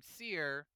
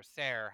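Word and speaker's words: Cere: And he meets another Jedi named Seer, or Ser, how they say it two different ways, but who knows of another Jedi who had hid away a Jedi holocron.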